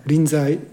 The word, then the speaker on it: Rinzai: This expression, the person who can be master in any place, came from Rinzai is Rinchi in Chinese pronunciation.